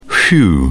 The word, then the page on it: whew: British English